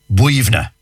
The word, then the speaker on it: Boyunagh: Like, for example, I know one, for example, one cemetery near Glenamedy, and it's spelled B-O-Y-U-N-A-G-H. B-O-Y. So you'd be saying Boyuna. No, yeah. Not a bit of it. B-O-Y-U-N-A-G-H is pronounced Buivna.